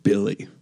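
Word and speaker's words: Willy Hernangómez: So the weird one, Willie Hernan Gomez, you pronounce it Billy.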